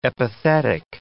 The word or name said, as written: epithetic